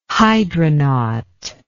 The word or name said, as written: hydronaut